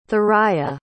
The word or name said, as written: thuraia